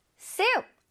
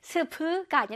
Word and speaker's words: soup: soup. 아, 수프, 국 나왔네요. 그쵸? soup 할 때 수프가 아니라 soup. 선생님 입모양 보세요. soup.